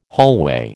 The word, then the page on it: hallway: American English